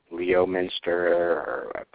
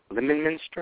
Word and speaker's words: Leominster: I would pronounce it Leominster, but I know that's wrong. I've been corrected on that before. Lemonminster or something like that, because it's not, to me that's so like a lemon, but it's L-E-O-M, and that's not how I spell or say lemon, but L-E O-M-I-N-S-T-E-R.